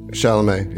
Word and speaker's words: Chalamet: So Terry looks like Timothy Chalamet. Am I saying that right? – Chalamet.